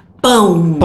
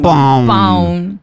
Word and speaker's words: pão: Cada vez que vos pronunciás, cada vez que vos veas una palabra que tenga la A, la O y la decimos tio, ¿no? Que es esta señal que tiene encima de la N, vos imagina que tienes que pronunciar así, pão.